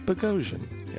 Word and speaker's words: Boghossian: We've And the correct pronunciation of his name. – Indeed. Like explosion. – Yeah. – As he explained. – Boghossian.